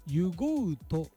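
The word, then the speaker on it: 油河内: 湯ごうとっていうんですね、これね、油に山水の川に内と書いて湯ごうと、うん、で彼岸花が道路脇の斜面を赤く彩っています。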